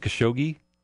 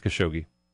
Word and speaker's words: Khashoggi: Khashoggi. I can't figure. I've heard a couple of different pronunciations, but I think it's Khashoggi.